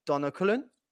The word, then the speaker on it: Donnarumma: And during the game, right in front of Conte was sat Donnarumma.